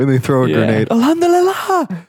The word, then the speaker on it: Alhamdulillah: Is that the Alhamdulillah? – Yeah, Alhamdulillah. Alhamdulillah. Yeah. – Yeah. – That is how you say it.